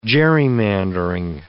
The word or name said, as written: gerrymandering